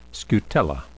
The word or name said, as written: scutella